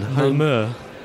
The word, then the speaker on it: Malmö: We're at Nordic Game, which is a video game conference in Malmö, Sweden. – Malmö. – Sorry? – Malmö. – Okay. – Turns out we've been saying it wrong.